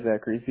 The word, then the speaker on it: Zackery: So, Zach, your first name is spelled Z-A-C? – K-E-R-Y. – K-E-I-Y? E-R-Y? – Yeah. – Zachary? – Z-A-C-K. Yeah, Zachary. Z-A-C-K-E-R-Y. – Okay. And what is your last name? – Solian. S-O-L-I-A-N.